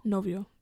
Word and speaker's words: novio: Yo, bio. Is that how you say it in Spanish? Yo, hobio. Yo. – Novio. – Novio. – Yeah, emphasis on no.